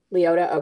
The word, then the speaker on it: Liotta: So my book of the week is Sweet Escape by Meredith Wild and Jillian Liotta. I don't know if I'm saying her last name right. I Leota. – apologize if I said it wrong. Liotta.